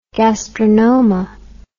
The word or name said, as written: gastrinoma